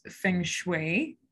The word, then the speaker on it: feng shui: It's how can you use feng shui, I said it correctly, hopefully, how can a person utilize it to shift their vibrancy and enhance their space? So you totally just nailed it.